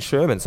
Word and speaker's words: Sherman: So have I been saying Sherman? So I've been saying it wrong? – No, you haven't. – You haven't. You've okay. – been saying it right.